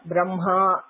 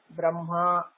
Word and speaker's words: ब्रह्मा: एवमेव ब्रह्मा इत्यत्र लेखनं ह म किन्तु उच्चारणं ब्रह्मा